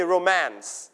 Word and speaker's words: romance: romance You stress on the second syllable นะครับเราจะไม่ออกเสียงพยางค์และไม่เน้นพยางค์แรกนะครับเน้นพยางค์ที่ 2อ่านว่า romance ไม่อ่าน romance